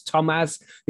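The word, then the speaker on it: Tomasz: The beating to death of, I don't know how to pronounce his name, it's Tomas. It's like Thomas without the H and it's got a Z on the end.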